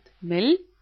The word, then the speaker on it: Mehl: Mehl, это мука, а пишется как Mehl, потому что мы помним, ха после гласной не читается, будет Mehl.